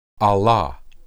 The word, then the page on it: alah: hebrew